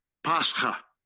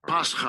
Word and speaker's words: Pascha: We're going to pronounce it. – Strong's G, 3957. Pascha. Pascha. Right.